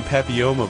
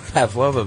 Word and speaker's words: papilloma: We also mispronounce HPV, which is... – I know. – Go ahead and say the real word. – Human papilloma virus. – Yeah. – Right? – Yep. And we said Pavlova virus.